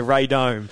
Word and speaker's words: radome: And the one I'm talking about now is this pronunciation right? Is Radome. – The Radome.